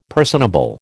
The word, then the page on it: personable: American English